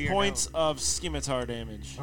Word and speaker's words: scimitar: He's going to try to swing at you with his scimitar.